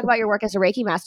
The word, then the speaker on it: Reiki: So one thing I really want to talk about is your work as a Reiki master.